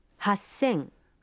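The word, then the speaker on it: Хасен (八千): Но и здесь произношение меняется. 3000 будет звучать как... – Санзен. – 8000... – Хасен.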